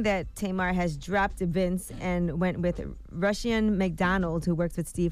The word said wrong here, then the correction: Rushion: Yeah, Rashawn. Is that how you say his name? – Rashawn, yeah. – It's Rashawn. – It's spelled R-U-S-H-I-O-N.